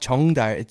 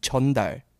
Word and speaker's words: 전달: Nope, you said it wrong. It's not 정달, it's 전달.